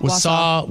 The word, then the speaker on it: Wausau: The mayor of, how do you say this, Wausau? – Wausau, Wisconsin.